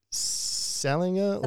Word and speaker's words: Salinger: Is it J.D. Salinger. Yeah. So you pronounce the G? – Yep.